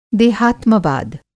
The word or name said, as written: देहात्मवाद